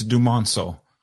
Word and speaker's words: Dumonceaux: It's Dumonceaux.